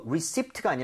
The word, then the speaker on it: receipt: 이거 그나저나 영수증 할때 이거 receipt가 아니라 Receipt. – 리시트입니다. P는 묵음입니다.